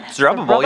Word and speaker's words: Zerubbabel: And it's they go home under a man named Zerubbabel. Say that right? – Zerubbabel, yeah.